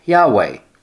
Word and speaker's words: Yahweh: That yod, which is the Y and the hey, and the wa and the final hey, when pronounced together sound like Yahweh.